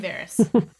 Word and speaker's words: Varys: Because people, some people like to pronounce his name Varus. – Varus. And I'm just like, that's not his name.